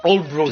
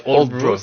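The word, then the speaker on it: Aldeburgh: Oui, ça s'appelle Oldbourg, ça s'écrit Aldebourg, mais ça se prononce Oldbourg,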